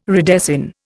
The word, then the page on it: redesign: Indian